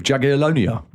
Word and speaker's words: Jagiellonia: Well, mate, they drew with Jagiellonia.